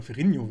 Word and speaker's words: Ferrigno: Äh, Lou Ferringo, äh, oder Ferrigno wird er ja ausgesprochen, der schon ganz, ganz viele Hulks damals gemimt hat, äh, also quasi alle, in allen Hulk, Hulk war irgendwie mit dabei.